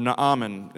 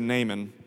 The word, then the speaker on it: Naaman: Now, in the book of 2 Kings chapter 5, we have a familiar story here about a man named Naaman, or Naaman is how you would say his name in Hebrew. We just say Naaman.